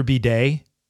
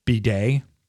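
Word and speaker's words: Badie: If you look at the box score, Tyler Bidet, which is how we're being told it's pronounced now, like the, you know, the thing that cleans your rear end, Bidet, he had 9 for 70.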